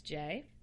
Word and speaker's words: Jaye: Where people ask me how to pronounce J because they see J-A-Y-E. And so it's like, they're like, oh, is it J-A?